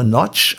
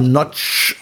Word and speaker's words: Nagy: Geschrieben Nagy, falls irgendwie. Nagy, aber Notch ausgesprochen. Also Nagy ähm, ist richtig.